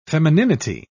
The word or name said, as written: femininity